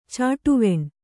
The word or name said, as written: ಚಾಟುವೆಣ್